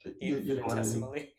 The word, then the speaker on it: infinitesimally: The Buddha says being born as a human being, the chances of that are even infinitesimally, I can't pronounce that word, infinitesimally. – Infinitesimally. – Yes, thank you.